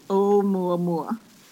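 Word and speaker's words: Oumuamua (ʻOumuamua): It's pronounced Oumuamua.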